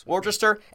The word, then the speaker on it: Worcester: Worcester High School, is that how you say it? – Worcester. – Worcester.